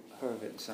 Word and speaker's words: Hurewicz: Oh yeah, H.U. Hurwitz, I always pronounced it. – Right. – Okay. – H-U-R-E-W-I-C-Z.